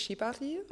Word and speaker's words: Shibari: So, weil im Japanischen so jede Vokal quasi, also gleich die Silben ausgesprochen werden, aber so im westlichen Kontext und der Art und Weise, wie wir sprechen, hörst du einfach ganz viel Shibari.